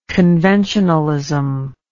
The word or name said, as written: conventionalism